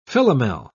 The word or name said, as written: Philomel